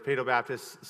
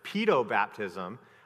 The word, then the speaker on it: paedobaptism: But there's different pronunciations of this. I refer to it as paedobaptism. Sometimes people refer to it as pedobaptism because in Latin the IE can have various pronunciations.